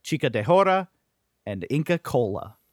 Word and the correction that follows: chicha de jora: Chicha morada and chicha de jora.